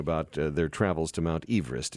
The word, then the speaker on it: Everest: But he didn't pronounce it Everest. He and his family pronounced Everest Everest. – Huh. I'll be darned. – Two syllables. – He's a Welshman? – Everest. Welsh, yeah. That's how it's supposed to be pronounced.